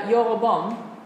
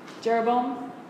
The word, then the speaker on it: Jeroboam: Now this is the same verb used of Yoroboam. Yoroboam. Jeroboam. Mixing Hebrew and English pronunciations.